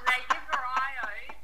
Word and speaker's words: Lagevrio: Lagevrio. – Lagevrio, thank you.